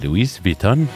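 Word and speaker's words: Louis Vuitton: هي أنا وجدت أنها تنطق بطريقتين, لويس فيتون ولوي بيتون, فبالتالي يعني أغلب مستمعينا سواء عاشة خليفة أمل كتبوا لويس بيتون, وبالتالي هي ماركة من الماركات العالمية.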